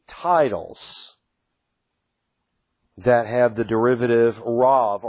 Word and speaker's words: Rav: The B can be pronounced with a V pronunciation, Rav.